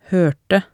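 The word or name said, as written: hørte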